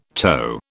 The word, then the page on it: toe: British English